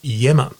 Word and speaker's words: Iemma: Then they picked a leader who's a lovely man, Maurice Yemmer, is the new premier, whose name is spelt I-E-M-M-A. It's an Italian surname, and I think with a Greek background, I-E-M-M-A, pronounced Yemmer.